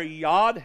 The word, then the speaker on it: Yod: And those letters are Yod, He, Vav, He. That's how they're pronounced.